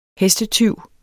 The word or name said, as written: hestetyv